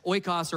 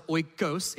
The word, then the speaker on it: oikos: This Greek word is oikos, O-I-K-O-S, oikos or oikos, if you like the yogurt, however you want to pronounce it, oikos.